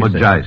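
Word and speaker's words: Mozhaysk: Mojaisk, with the accent on the second syllable.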